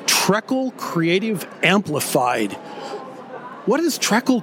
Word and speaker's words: Trekell: Hey, it's Trekkle. It's Trekkle, Trekkle, whatever you want it to be. So I was trained to say Trekkle.